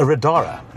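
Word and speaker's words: Radar: I'm sitting in a Radara. Is that the correct way of pronouncing it? That's correct, yeah.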